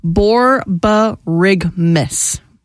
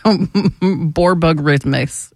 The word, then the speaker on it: borborygmus: It's pronounced borborygmus. It's spelt borborygmus, but it's pronounced borborygmus.